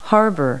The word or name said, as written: harbour